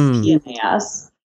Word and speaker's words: PNAS: Yes, it is PNAS, perhaps for reasons you have just alluded to. It is PNAS. Sometimes PPNAS, which is the prestigious Proceedings of the National Academies of Science. Sometimes PNAS, papers that Nature already saw.